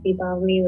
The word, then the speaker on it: Deepavali: But there's like two different pronunciations of it. There's Diwali, which is the North Indian pronunciation, and then there's Deepavali, which is the South Indian pronunciation.